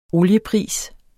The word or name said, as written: oliepris